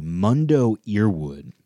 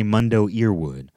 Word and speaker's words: Mundo Earwood: And Mundo is spelled M-U-N-D-O, last name E-A-R-W-O-O-D. Mundo Earwood. What do you say about that? You say Mundo Earwood.